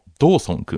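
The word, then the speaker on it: 同村: で、主人公はですね、この春地獄の進級試験をなんとか乗り越えて、なんとか 5 年生になった医学生のドーソン君、同じ村と書いてドーソン君ですね、まあ、この青年が主人公なんですが、まあ、医学部ではですね、 5 年生になるとポリクリという実習をします。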